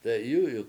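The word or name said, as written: t'e'yú'yuk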